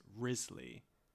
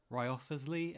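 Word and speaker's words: Wriothesley: Henry's death was finally revealed to Parliament on the 31st of January by an emotional Thomas Risley, his Lord Chancellor, whose name I will pronounce as Risley, though you are free to disagree. Ryoffersley, anyone? Never mind.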